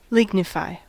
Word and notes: lignify: American English

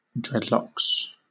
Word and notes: dreadlocks: British English

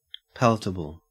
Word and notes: palatable: Australian English

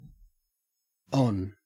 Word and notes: on: Australian English

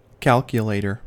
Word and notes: calculator: American English

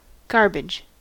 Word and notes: garbage: American English

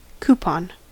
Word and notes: coupon: American English

